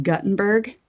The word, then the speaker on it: Gutenberg: But anyway, did you know there's a city in Iowa spelled Gutenberg but pronounced Guttenberg? – That doesn't surprise me.